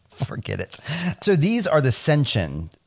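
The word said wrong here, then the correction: Seanchan: Shanshin. I'm terrible at pronunciations, but it's spelled like Sean Marciniak. Shanshin. Like Sean, C-H-A-N.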